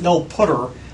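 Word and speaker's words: nullptr: And I deliberately pronounce it null putter to make it sound different than null pointer.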